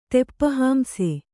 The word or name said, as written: ತೆಪ್ಪಹಾಂಸೆ